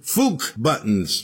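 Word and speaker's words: Fuck Buttons: Liars did Hold You Drum, another band called Magic Shop, Interstellar Car Crash, and we heard, just Fook Buttons, but it rhymes with luck, and Brain Freeze was the song that started the set.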